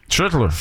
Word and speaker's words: Schlittler: Schlittler. J'aime ça de la manière tu l'as très bien prononcé.